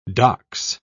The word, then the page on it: docs: British English